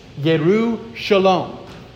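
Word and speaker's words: Yerushalom (ירושלים): And it's pronounced like this, Yerushalom.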